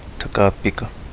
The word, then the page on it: Tagapiga: Kapingamarangi